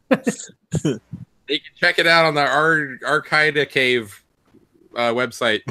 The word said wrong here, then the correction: Arcade Archive: I can't say it right. Arcade Archive.